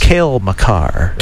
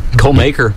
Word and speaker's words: Makar: It's Cale Makar. – Cale Makar. Thank you. – What you're looking for. – I think I called him Cole.